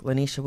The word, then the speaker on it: Lanicia: I'm on Facebook, Lanisha Williams.